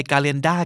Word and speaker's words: calendar: หรือว่าเออคือครึ่งเสียงนิดเดียวแล้วมีตัวอาด้วยนะฮะหมายเหตุว่าในอเมริกันอิงลิชซะเป็นส่วนใหญ่นะครับในส่วนของสำเนียงบริติชต้องแล้วแต่คำมาดูกันนะฮะตัวอย่างที่ชัดเจนเลยคำแรกปฏิทินครับ CALENDAR อ่านเป็นภาษาไทยคือ calendar ถูกไหมฮะแต่ถ้าเกิดเราไป calendar กับฝรั่งอเมริกันเขาอาจจะงงได้นะเพราะจริงๆมันออกเสียงว่า calendar เหมือนสะกดด้วย er เลยนะฮะ